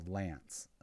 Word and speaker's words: LAANC: So they actually created a stupid, super easy way to gain access through the lance. The L-A-A-N-C, but everybody pronounces it as lance.